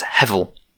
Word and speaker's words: hevel: And so the Hebrew word behind that, that's hevel. Something pronounced a little bit like that.